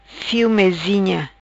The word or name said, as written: Fio Mezinha